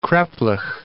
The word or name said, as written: kreplach